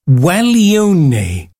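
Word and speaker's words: Guaglione: Right, I've had to look up the pronunciation of this, and I do believe it's Gualione by Perez Prado,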